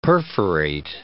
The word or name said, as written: perforate